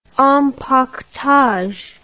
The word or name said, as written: empaquetage